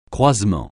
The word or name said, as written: croisement